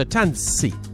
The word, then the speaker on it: Tanssi: Like, for example, in the album name, T-A-N-S-S-I. But you kind of have to go Tans... And, well, kind of separate it, Tans, and then C, and then take out the gap. So Tansi. So you pronounce both S's.